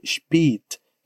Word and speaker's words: spät: A pronúncia é spät. Spät. Com o som de E mesmo. Em via de regra, não estaria errado se você pronunciasse com o som de E. Spät. Spät. Porém, é menos comum.